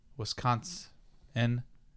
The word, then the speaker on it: Wisconsin: Let me pronounce it right. Wisconsin. – Yeah, there's no T. – No, there is no T at the end.